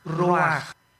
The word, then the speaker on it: ruach: El ruaj, que se escribe R-U-A-C-H, ruach en el hebreo. Y se pronuncia igual, ruaj.